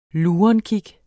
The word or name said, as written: lurenkig